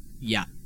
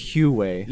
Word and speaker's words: Huawei: It looks like Hawaii, but it's like Huawei. – I know what you're talking about. – It's Huawei.